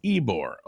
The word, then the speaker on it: Ybor: um, city in Tampa is Cigar City, pronounced Ybor. Oh, why? Oh, there it is. Okay. Um, Ybor Patrick City, Morris baby.